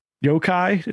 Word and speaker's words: yokai: Its story hook is that there's yokai. Am I pronouncing that right? – Yeah.